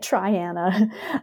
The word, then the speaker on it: Triana: It took me a while to learn how to say it, Triana, Alabama. And yeah, and I came across Triana's quote unquote DDT problem.